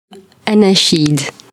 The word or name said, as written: anachid